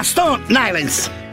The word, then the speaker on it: Stone Island: Di Jim, che c'è la questi jeans della Stone Islands. – Stone Island. – Ma perché scrivono? Scrivono come si legge. – Si chiama così, Stone Island.